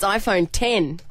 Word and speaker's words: iPhone X: Wrong because it's actually pronounced as a Roman numeral to celebrate the 10th anniversary of the iPhone. It's iPhone 10.